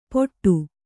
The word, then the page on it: ಪೊಟ್ಟು: poṭṭu